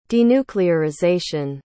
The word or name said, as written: denuclearization